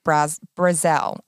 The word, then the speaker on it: Brazel: Mac Brazel, I think is how you say his last name. B-R-A-Z-E-L. I think it's Brazel. That's how they pronounced it.